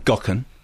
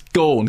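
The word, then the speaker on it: Gaughan: By the way, it's not Gokken. – It's Gorn, gone. – Well, it is Gorn. According to James... it says Gorn is pronounced akin to Vaughan, as in Matthew, rather than Gokken.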